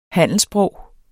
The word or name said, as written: handelssprog